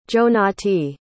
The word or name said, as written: jonati